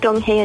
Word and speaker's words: Donghae: Donghae and Onyo.